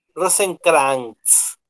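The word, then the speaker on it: Rosenkrantz: O como se pronuncia, es Rosen, Krantz, Rosencrantz.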